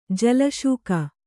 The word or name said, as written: ಜಲಸೇಕ